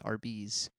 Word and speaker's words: Arby's: we would get dinner before we went to RA's, and Dad would go to... – I believe it's pronounced Arby's.